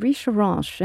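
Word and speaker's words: Richerenches: It's called Richeranche, and I'll spell that. It's R-I-C-H-E-R-E-N-C-H-E-S.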